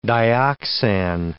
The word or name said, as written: dioxan